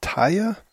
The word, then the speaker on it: TAIA: So this is a pretty lengthy interview or report on a on a paper that was released by, I guess it's called, pronounced TAIA, T-A-I-A Global, which is Jeffrey Carr's company.